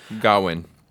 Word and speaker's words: Gawain: Gawain. – It's pronounced both ways, technically, but Gawain for our sake.